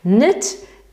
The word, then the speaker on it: knit: Knit pisze się w ten sposób, ale oczywiście K się nie wymawia i to jest knit.